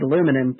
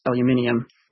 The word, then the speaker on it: aluminium: One British-Australian superiority when it comes into play, it's you pronounce it aluminum, but it's supposed to be pronounced aluminium.